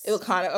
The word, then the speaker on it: Ilocano: So your mother's people are the Ilocano. Am I saying that right? – Yes. – Ilocano.